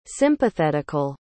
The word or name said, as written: sympathetical